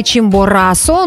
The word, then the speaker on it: Чимборасо: И мы отправимся туда, к одной из самых удивительных гор на нашей планете, вулкану Чимборасо, точнее Чимборасо, но на самом деле мне выдал ударение и то, возможно, это специфика местного языка. Поэтому будем говорить Чимборасо, как говорят иностранцы.